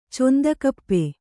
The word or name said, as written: ಚೊಂದಕಪ್ಪೆ